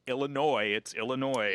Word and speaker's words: Illinois: I spent the whole time I was getting ready to come to college, I explained to people, it's Illinois. – Yeah. – And the first placement test I had here, the proctor giving the placement test pronounced the S on the end, and I just wanted to die.